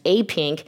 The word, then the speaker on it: APIENC: But they lead an organization, and it's called API Equality Northern California, but pronounced APINK.